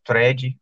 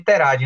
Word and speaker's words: thread: Mas eu li uma, uma, uma ouvinte da gente me ensinou como é que fala esta palavra, é thread, é uhum. trend, Terad. – É, é, eu chamava de Terad, né?